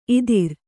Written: ಇತ್ತೆರ

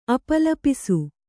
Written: ಅಪಲಾಪಿಸು

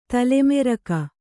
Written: ತಲೆಮೆರಕ

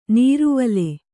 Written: ನೀರುವಲೆ

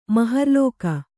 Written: ಮಹರ್ಲೋಕ

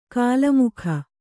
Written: ಕೋಲುಮುಕ್ಕ

